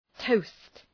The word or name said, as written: toast